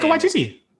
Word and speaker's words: Waititi: or Taika Waititi, I just butchered his name, didn't I? – It's Taika Waititi.